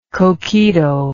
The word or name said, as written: coquito